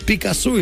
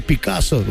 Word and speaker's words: Пикассо: Пабло Пикассо или Пикассо. У нас ударение ставят по-разному.